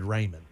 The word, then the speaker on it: Ramen: It's spelled Ramen, but it's said Raymond.